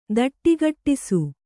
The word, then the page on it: ದಟ್ಟಿಗಟ್ಟಿಸು: kannada